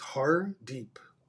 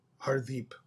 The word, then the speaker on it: Hardip: So I spell it H-A-R-D-I-P. But if you pronounce it, it's Hardeep, Hardeep.